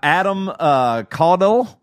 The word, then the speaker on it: Adam Caudill: Like these folks, we got Adam Caudill. Am I saying that right, man? I don't know. – You nailed Emerson Jacob Fleury, Dante Giancola, Lindy Holt, Samuel Greer, and Nick Watson.